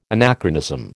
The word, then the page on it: anachronism: American English